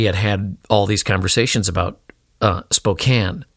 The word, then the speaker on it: Spokane: In particular, the one I remember the most is how I pronounced Spokane. No, Spokane. It's Spokane. I pronounced it Spokane.